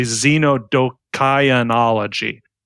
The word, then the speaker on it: xenodokionology: I need to correct my pronunciation. That's actually xenodokionology. Xenodokionology.